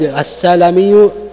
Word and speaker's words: السلمي: السلمي يقول لك بفتح المهملة واللام من بني سليمة بطن من الخزرج أنصاري، هذا السلمي، والسلمي بضم المهملة وفتح اللام نسبة إلى بني سليمة ليسوا من الأنصار أصلا، فتختلف يعني الأمور، هذا يدخل في المؤتلف والمختلف.